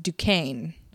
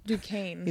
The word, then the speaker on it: Duquesne: It's pronounced Duquesne. – Duquesne.